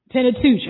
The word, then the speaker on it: Pentateuch: Pentateuch.